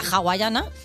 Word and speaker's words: hawaiana: Porque hay gente que la pizza con piña o la hawaiana, ¿vale? Que se escribe con H, pero se pronuncia con J.